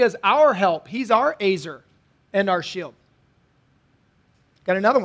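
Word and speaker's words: ezer: In the original Hebrew, the word is azer. Go ahead and write that down. It's E-Z-E-R, but it's pronounced azer.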